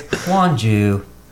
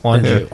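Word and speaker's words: Huangjiu: It's not that one I said. It's Huanju. – Yes. – Huanju.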